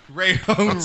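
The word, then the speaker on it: Rajon Rondo: Ray Hone Rondo.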